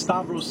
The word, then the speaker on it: Stavros: S-T-A-V-R-O-S, Stavros. – Okay. – There's Greek in there, because Stavros sounds like choppy, but Stavros, if a Greek person says it, it flows better.